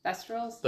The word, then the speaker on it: Thestrals: That's how you say it, right? Thrussels? – Thestrals? – The actual horses.